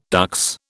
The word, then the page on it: dux: British English